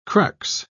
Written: crux

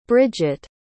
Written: Brigitte

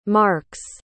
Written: marx

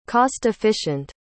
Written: cost-efficient

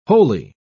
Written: holy

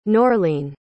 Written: Norlene

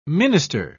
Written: minister

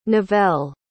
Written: novelle

